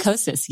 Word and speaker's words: Kosas: Kosas? – Kosas, yes.